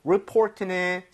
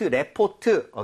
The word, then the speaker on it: report: 그리고 report는 사실 리포트, 레포트, okay? So 예. – 신고, 보고서가 되겠습니다. Report, 이렇게 발음할 때 레가 아니라 report. – Report. – 요거만 주의해 주세요.